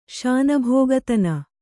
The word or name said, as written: ಶಾನಭೋಗತನ